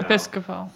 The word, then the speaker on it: Episcopal: The Episcopal, I don't know how to say it. – Episcopal.